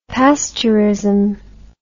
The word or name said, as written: pasteurism